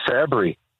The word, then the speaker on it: Fabry: Mr. Fabry. – I'll take it. I'll take Fabry. – Did I get that right? – You got it.